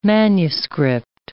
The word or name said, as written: manuscript